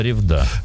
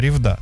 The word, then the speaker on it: Ревда: Я сейчас посмотрел на ударение, я тебе сказал, что Ревда, правильно, Ревда это в Мурманской области, а вот на Урале Ревда. Это Ревда.